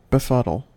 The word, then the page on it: befuddle: American English